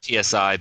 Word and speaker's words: Psi: Yeah, it's spelled PSI, but I call it Psi or C if you want to be correct about your Greek.